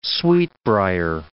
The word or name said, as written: sweetbrier